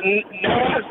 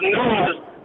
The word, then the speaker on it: Nas: It's not Nas, it's pronounced Nas, and it comes from a king named King Nazir.